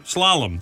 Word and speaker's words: slalom: I can never pronounce this word. How do you say it again? Google, how do you say it? – Slalom. – Slalom, that's it.